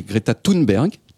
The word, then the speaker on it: Greta Thunberg: je me demande toujours comment est-ce qu'on prononce son nom, mais je crois que c'est Greta Thunberg